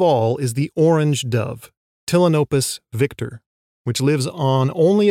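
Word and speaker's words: Ptilinopus: All of them are in the genus Tillinopus. That's with a silent P, like pterodactyl or ptarmigan.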